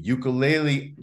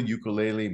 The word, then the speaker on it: ukulele: His name is Vinny Sturgeon, and he is a ukulele artist and inventor of the mindful ukulele method. And I know I'm saying it wrong. It's ukulele. But anyway, thank you for being here.